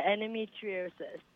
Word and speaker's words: endometriosis: But you mentioned to people, and I'll probably pronounce it wrong, that you've had endometriosis. Did I say that right? – Yeah, endometriosis.